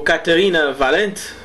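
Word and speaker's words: Caterina Valente: A primeira música que eu escolhi é de Katharina Valente, ou Katharina Valente, para ser mais correto na pronúncia.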